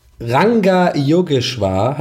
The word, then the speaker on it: Ranga Yogeshwar: Wie spricht man das aus? Yoga. – Rangeschwar. – Ranga Yogeshwar heißt der.